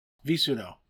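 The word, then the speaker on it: visudo: He did mention VI sudo or V sudo or Vis sudo or however you want to pronounce it. – V sudo.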